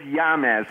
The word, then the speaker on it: James: Is it James or Yamez? – It's Yamez.